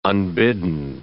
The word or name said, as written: unbidden